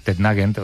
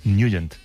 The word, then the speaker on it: Nugent: Hombre, siempre lo hemos llamado en el barrio Tednagent o Tednagent. – Claro, yo era de Tednagent. – Pero la pronunciación correcta es Nugent. – Te fuiste a Washington y descubriste que era Nugent.